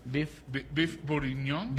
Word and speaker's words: bourguignon: Beef bourguignon.